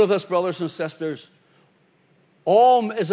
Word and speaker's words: Aum: It is actually pronounced A-U-M, OM.